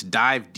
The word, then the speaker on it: DIIV: It's Dive, D-I-I-V. It was called, you spell it the regular way, but it's after the Nirvana song.